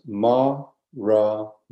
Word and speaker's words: Maranatha: It's four syllables, Maranatha. Maranatha. Four syllables.